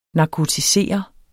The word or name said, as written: narkotisere